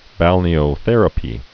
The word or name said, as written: balneotherapy